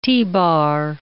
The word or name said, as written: T-bar